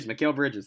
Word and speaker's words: Mikal Bridges: Mikal Bridges.